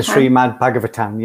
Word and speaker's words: Srimad Bhagavatam: The Srimad Bhagavatam, yeah.